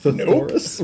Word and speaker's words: thesaurus: A thothorath? Am I saying that right? – Thesaurus. Nope.